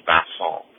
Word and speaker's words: Vincent: So the beginning of his name, his first name, has two N's, and each of those N's nasalize the preceding vowel and are also silent. So his first name is pronounced Vincent. And in English, the word Vincent, the emphasis is on the first syllable, Vin, but in this case, the emphasis is on the second syllable. So his first name is Vincent.